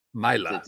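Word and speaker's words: Maile: No, they signed Luke Mail. And actually, I presume that's how you pronounce that. Now I'm looking at it. It has to be. – Myla. – Luke Myla.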